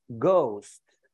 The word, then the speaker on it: ghost: So this is how I'm starting the day with bad pronunciation, because you said ghast. It's not ghast. It's ghost. And it's not drawing, you know, it's drowning.